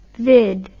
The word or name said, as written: vid